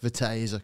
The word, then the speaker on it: Viteza: I said that wrong. – What is it? – Viteza, because I robbed it off a road sign in Romania.